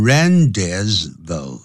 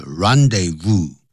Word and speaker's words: rendezvous: The Moulin Rouge was the rendezvous.、啊、这个是不能念成 rendezvous. 那不是因为不是英文是 rendezvous.